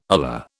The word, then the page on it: ular: British English